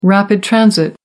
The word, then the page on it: rapid transit: American English